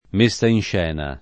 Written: messa in scena

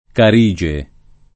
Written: Carige

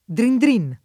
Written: drindrin

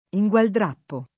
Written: ingualdrappare

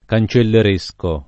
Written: cancelleresco

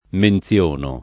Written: menzionare

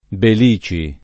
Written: Belici

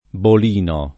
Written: bolinare

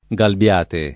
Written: Galbiate

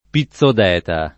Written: Pizzodeta

